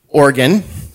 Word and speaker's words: Oregon: I am a pastor in Oregon, not Oregon, Oregon, that's how we pronounce it.